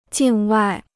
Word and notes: 境外: jìng wài

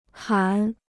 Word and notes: 喊: hǎn

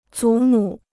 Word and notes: 祖母: zǔ mǔ

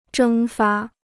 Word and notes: 蒸发: zhēng fā

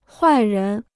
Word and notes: 坏人: huài rén

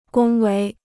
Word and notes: 恭维: gōng wei

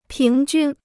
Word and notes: 平均: píng jūn

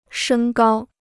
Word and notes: 升高: shēng gāo

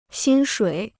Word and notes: 薪水: xīn shuǐ

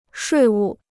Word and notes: 税务: shuì wù

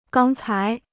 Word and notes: 刚才: gāng cái

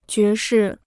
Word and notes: 爵士: jué shì

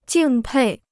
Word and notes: 敬佩: jìng pèi

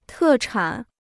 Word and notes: 特产: tè chǎn

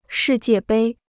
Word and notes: 世界杯: shì jiè bēi

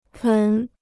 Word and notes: 盆: pén

